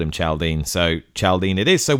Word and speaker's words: Chaldean: surely this Chaldean should be pronounced Chaldean or Chaldean, as in the people of Chaldea rather than the fictional village in West Berkshire, which doesn't exist.